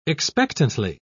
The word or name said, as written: expectantly